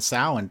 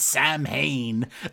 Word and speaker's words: Samhain: That he's like, oh, so, like when she's like, so what's the deal with that knife and the Samhain thing? He's like, oh, well, first of all, let me correct you. It's Samhain. That's how you pronounce it. That's how I pronounce it.